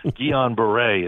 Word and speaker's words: Guillain-Barre: I took Spanish and Latin in school, but Guillain-Barre is the official Guillain-Barre.